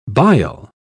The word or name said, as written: bile